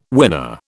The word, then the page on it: winner: British English